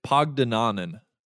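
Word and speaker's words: Pagdanganan: Bianca Pandiagonon, am I saying that right? I really hope so. I hope I just did not butcher the hell out of her name. – I wouldn't say you butchered the hell out of it, but I will jump and say it's Pogdenonen.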